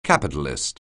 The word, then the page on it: capitalist: anglais